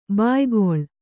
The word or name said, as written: ಭಯಾಕುಳೆ